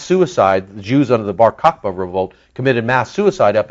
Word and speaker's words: Bar Kokhba: It's called the Bar Kokhba Revolt.